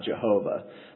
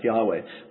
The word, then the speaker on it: Yahweh: It's more likely that it would be something like Yahweh. It's definitely not Jehovah. But probably it would have been pronounced something like Yahweh.